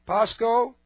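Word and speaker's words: Pasco (πάσχω): That's P-A-S-C-H, long O. Pasco.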